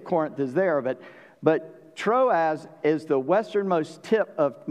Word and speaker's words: Troas: He's going to tell us he's going to a place called, we pronounce it Troas, T R O A Z.